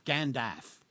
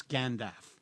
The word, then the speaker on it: Gandalf: The question was, how do you pronounce G-A-N-D-A-L-F? And, in his very heavy Oxfordian come South African accent, he said, well, it's Gandalf. And I said, okay, can you tell me more? And he says, well, it rhymes with half, like half a sixpence.